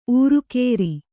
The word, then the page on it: ಊರುಕೇರಿ: ūrukēri